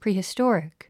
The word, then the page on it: prehistoric: prìːhistɔ́rik; プリヒストォリック